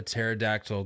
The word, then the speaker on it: pterodactyl: Why can't you hear a pterodactyl go to the bathroom? – I don't know. – Because the P is silent.